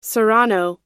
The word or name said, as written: serrano